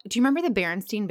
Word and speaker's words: Berenstain: She posted, it's Berenstain Bears, like the way it's spelled.